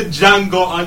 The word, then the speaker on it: Django: The Django Unchained. – No. The D is silent.